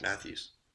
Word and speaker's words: Matthews: We're hoping that Tajae Sharpe and Rashard Mathis. – Matthews. – Matthews. I knew I was going to say it wrong.